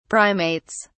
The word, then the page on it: primates: British English